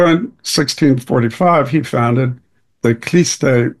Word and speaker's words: Khlysty: So one of the mystical groups that I was pretty much unaware of, except for that vague thing I was talking about, it's spelled K-H-L-Y-S-T-Y. Kliste is what they call them.